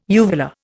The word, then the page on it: uvular: Indian